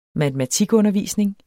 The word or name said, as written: matematikundervisning